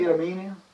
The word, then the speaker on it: Idumea: Idumea.